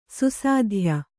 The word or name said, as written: ಸುಸಾಧ್ಯ